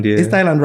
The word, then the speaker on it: Thailand: You're really pronouncing the H of Thailand. – Is it my way? It's what? See, let me hear. – Thailand. – It's Thailand. They don't pronounce the H. No. The H is silent in Thailand.